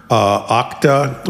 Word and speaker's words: Okta: We have a cybersecurity company out there, Okta, I believe is how you pronounce it.